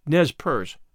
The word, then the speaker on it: Nez Perce: In Idaho, there is a county named Nez Perce, also a town named Nez Perce, written as one word. Both are locally pronounced in the American fashion to rhyme with verse.